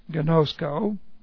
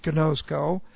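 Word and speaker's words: ginosko: Here's gnosko. G-I-N, long O-S-K, long O, gnosko.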